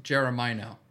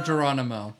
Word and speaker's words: Geronimo: Look, you're talking to the guy who, for like about 2 years, thought it was pronounced Jeremino instead of Geronimo.